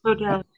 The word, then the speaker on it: Rodel: I did reach out to the author of the letter from Rodeo. Is that the name of the company, if I'm saying that right? – Rodel. – Yeah, Rodel.